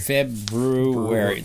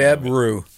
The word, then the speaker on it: February: Yeah, I'm aware of that, but I never take the time to pronounce it correctly. February. Like you're going to brew something right in the middle. – February.